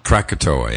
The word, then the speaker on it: Krakatoa: I'm not pronouncing that, I'm sure, properly. Krakatoa Rock. – Krakatoa? – Krakatoa, yeah.